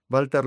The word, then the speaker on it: Walter: Este italiano, se escribe con W, pero se pronuncia con B, Walter Longo, ¿ven?